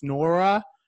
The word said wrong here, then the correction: Nwora: Nwora. – Thank you. Jordan Nwora.